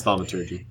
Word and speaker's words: thaumaturgy: It's pronounced thaumaturgy.